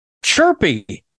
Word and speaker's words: chhurpi: From Nepal, it's Chirpy. That's spelled C-H-H-U-R-P-I.